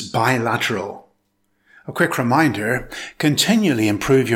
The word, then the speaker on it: bilateral: It consists of four syllables. Stress the second syllable. It's pronounced bilateral.